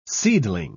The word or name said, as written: seedling